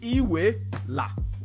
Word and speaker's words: Iweala: Everybody who doesn't know how to say it, this is how you say it. Iwe. Say it with me. Iweala.